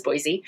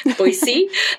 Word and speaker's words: Boise: So natively, because I'm not originally from here, I thought the name of the place was Boise, but I was given that insider information. I was able not only to use the correct pronunciation, right? Boise, the insider version, yeah.